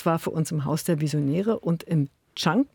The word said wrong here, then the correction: Kenk: wenn ich es richtig ausspreche, Andrea. Hallo. – Nee, Kenk. – Kenk. Ah, direkt falsch gemacht.